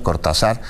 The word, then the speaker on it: Cortázar: Y ayer domingo, durante un trayecto sobre la carretera Cortázar Jaral del Progreso, así se pronuncia, Cortázar, pues fue intimidado el convoy en el que viajaba Alda Pacheco Juárez, candidata de Movimiento Ciudadano a una diputación federal.